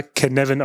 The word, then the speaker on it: Cynefin: Also, die meisten von euch, oder wenn ihr darüber gehört habt, werdet ihr wahrscheinlich das Ganze unter Cynefin oder Kinefin verstanden wissen. Ja? C-Y-N-E-F-I-N. Es wird aber Canavan ausgesprochen. Ist ein walisisches Wort und das steht im Endeffekt für Herkunft, Lebensraum, Platz.